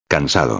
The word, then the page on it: cansado: espanhol